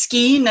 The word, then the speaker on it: skein: I was today years old when I learned it's pronounced skein and not skein or whatever. – I think it's skein. I think I checked.